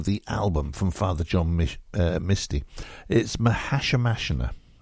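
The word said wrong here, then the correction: Mahashmashana: oh dear. Mahash Mashana. There we go.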